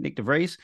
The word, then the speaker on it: Nick de Vries: That's how you say it, Nick de Vries, the Dutchman dumped after 10 races with AlphaTauri.